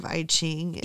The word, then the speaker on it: I Ching: So Lu, the wife, is actually practicing the art of I Ching. It's pronounced that way.